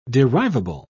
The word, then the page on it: derivable: British English